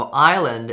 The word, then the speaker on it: island: So, island is actually spelled I S L A N D, uh, which means the S is silent,